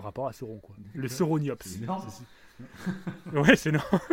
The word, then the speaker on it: Sauroniops: Alors, ça s'écrit comment par contre Alors, Sauroniops, alors ça s'écrit comme Sauron, S-A-U-R-O-N. Sauroniops.